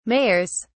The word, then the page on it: mayors: British English